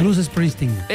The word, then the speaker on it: Bruce Springsteen: Bruce Springsteen.